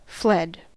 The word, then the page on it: fled: American English